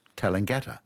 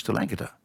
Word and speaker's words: Tallangatta: Well, when I came back to live in Australia, I thought it was Tallangatta in Victoria. And, of course, it's not. It's Tallangatta.